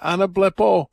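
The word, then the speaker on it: anablepo (ἀναβλέπω): I think that's a glorious word, anablepo. Anablepo is how you pronounce it in Greek.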